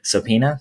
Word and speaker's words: subpoena: with oaths Sopina, sopina, sopina. – Yeah, I don't, don't pronounce, pronounce the B.